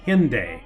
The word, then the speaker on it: Hendaye: Hendaye is pronounced Hendaye, though if you were to spell it the way we would say it in America, it looks like Hendaye, but the correct pronunciation is Hendaye.